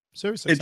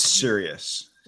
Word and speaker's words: Sirius: Is it Sirius or Cyrus? Sirius XM? – Sirius. – You muted yourself. – It's serious. – Yeah, it's serious. Okay, so like serious, like I'm serious.